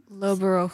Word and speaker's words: Loughborough: Lougheboroughg. How do you pronounce that? – How do you pronounce that? – Loughborough. – Loughborough. – Correct. Yeah. It's correct. Close. It's Loughborough.